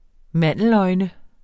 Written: mandeløjne

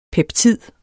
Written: peptid

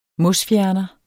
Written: mosfjerner

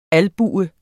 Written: albue

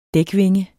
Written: dækvinge

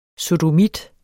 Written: sodomit

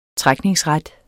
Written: trækningsret

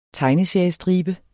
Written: tegneseriestribe

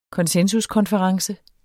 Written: konsensuskonference

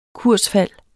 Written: kursfald

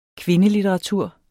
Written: kvindelitteratur

